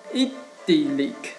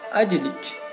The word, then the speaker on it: idyllic: 今天给大家分享的趣味单词是 idyllic，还有另外一种读法读成。 idyllic，咱就这次就用它的这个第二个读法 idyllic 来记这个单词。